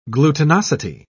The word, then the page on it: glutinosity: British English